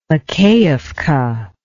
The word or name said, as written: makeevka